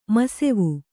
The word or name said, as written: ಮುಸುವ